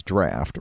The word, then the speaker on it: draught: So they just made their beer called, it's spelled draught, but we know it means draft, right?